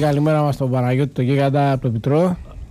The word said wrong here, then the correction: Μπιστρό: Μπιτρό δεν υπάρχει. Μπιτρό. – Ναι. – Μπιστρό λέγεται. – Μπιστρό. Εντάξει, μπιστρό. Δεν τονίζετε πολλές φορές το S.